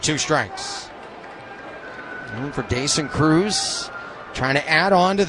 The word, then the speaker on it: Croes: Now, and Cruz spells his last name C-R-O-E-S. But it is pronounced Cruz. And I actually have it spelled in my book, C-R-U-Z.